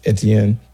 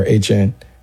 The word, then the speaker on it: Etienne: Alvin Kamara is still slated to be back, and he's joined by Travis Etienne, or Etienne. He changed his pronunciation. Travis Etienne take over in the backfield.